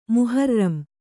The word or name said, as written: ಮುಹರ್ರಮ್